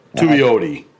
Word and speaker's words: Tuioti: We got our first taste of our new defensive line coach, Tony Tuioti. Did I butcher that name? – Tuioti. – Tuioti, okay. All right.